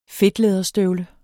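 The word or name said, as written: FEDTLÆDERSTØVLE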